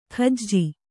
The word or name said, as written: ಕಾಜಿ